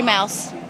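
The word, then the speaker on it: Miles: Lori Mouse. – How do you spell that? – L-O-R-I-M-I-L-E-S.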